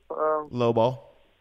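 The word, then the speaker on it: lowball: They tried to, slow ball, how you say it? – Low ball.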